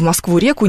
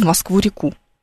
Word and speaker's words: Москву-реку: Вы знаете, что, например, вы купаетесь в Москве-реке, и прыгаете с моста в Москву-реку. А не в Москву-реку, не в Москва-реку и никак иначе. Прыгнуть с моста в Москву-реку. Mm-hmm. Вот такое ударение.